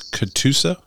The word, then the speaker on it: Catoosa: And because you're giving it through And tell me, it's Catoosa. Is that right? – Correct. – How can you spell it? Can you spell it for me? – C. It's C A T O O S A.